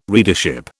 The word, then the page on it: readership: British English